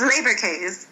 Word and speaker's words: Leberkäse: Am I saying... What is? How do you say it? – It's Leberkäse.